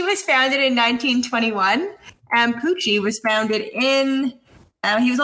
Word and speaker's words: Pucci: It's spelled like Gucci, but with a P. – Which company is older, Gucci or Pucci? – Probably Gucci.